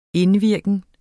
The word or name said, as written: indvirken